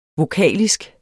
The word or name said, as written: vokalisk